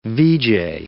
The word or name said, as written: veejay